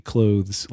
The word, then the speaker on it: clothes: you pronounce the word C-L-O-T-H-E-S and the word C-L-O-S-E, clothes like clothing and clothes like close the door, the same. Most people say clothes and clothes.